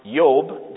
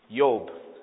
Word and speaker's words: Job: Job, which in the Hebrew would be pronounced Yob. They don't have J's. It would be a Y. Yob.